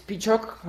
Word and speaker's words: Piciok: On LoudTalk, my nickname is Picok, like almost everywhere on the internet. It's spelled P-I-C-I-O-K.